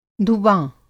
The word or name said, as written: धुवाँ